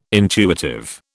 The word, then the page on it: intuitive: British English